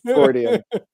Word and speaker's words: Freudian: I think you added an extra thought in there. It's just Freudian.